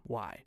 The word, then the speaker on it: Wye: And yes, it is pronounced Wye. W-Y-E.